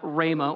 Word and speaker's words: Ramah: Then they went back to their house at Ramah, or Rama, depending upon your pronunciation.